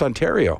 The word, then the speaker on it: Ontario: I'm like, I think it's pronounced Ontario. And he was like, oh, thank you for that.